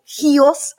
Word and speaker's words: Chios: or Chios, as my mum always tells me to say it. – So what pronunciation on the key? – Yes, it's the he. It's like the Greeks go, he, there's a lot of he in this.